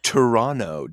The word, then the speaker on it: Toronto: Toronto. Don't pronounce the second T.